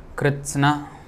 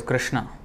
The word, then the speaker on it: Kritsna: Karmani akarma yah pashyet akarmani cha karma yah sah buddhiman manushyeshu sah yuktah kritsna karma krit. So sometimes devotees pronounce this wrongly as Krishna. It is Kritsna. Kritsna means all, you know.